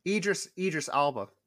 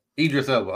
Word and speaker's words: Idris Elba: how you say his name? El Cibra, playing the role of Blade. – Idris Elba. – Idris Elba, okay.